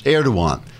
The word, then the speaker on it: Erdoğan: The Europeans put down the riots, which angered President Erdogan, which is pronounced Erdoğan because the G is silent, as in hottest.